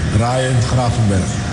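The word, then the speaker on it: Ryan Gravenberch: Ryan Gravenberg.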